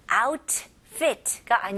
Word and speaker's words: outfit: 그래서 outfit가 아니고요. T를 아주 가볍게 outfit. 이렇게. 그래서 혀가 그 입 천장을 닫자마자 바로 F로 가는 거예요. outfit. Fit. Outfit. 네, 이해하셨나요? T 발음 그렇게 많이 안 들리죠?